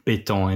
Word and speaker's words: béton: which is B-E-T-O-N, pronounced in different ways according to the country, béton in French.